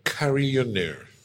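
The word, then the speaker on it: carillonneur: Carillionaire. – Is it a French word? It's a French word. In American, they say carillion.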